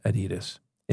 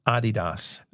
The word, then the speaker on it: Adidas: But believe it or not, my old roommate used to work for Adidas, and it is not pronounced Adidas. It's Adidas. It's a German company.